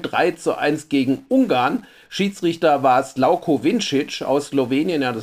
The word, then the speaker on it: Slavko: Und ich glaube, wir müssen den eben Slavko nennen, so wie Slavko Ausenik oder Peter Preutz. Also dieses V in Vincics Vornamen wird wohl als U ausgesprochen.